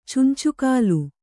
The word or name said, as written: ಚುಂಚುಕಾಲು